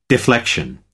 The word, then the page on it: deflection: American English